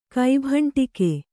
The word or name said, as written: ಕೈಭಂಟಿಕೆ